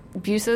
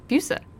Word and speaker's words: Busa: Busa is good. – But how do you say it? – Busa.